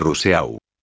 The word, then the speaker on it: Rousseau: Rouxot se pronuncia igual que Rousseau.